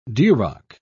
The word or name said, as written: duroc